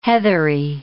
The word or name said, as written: heathery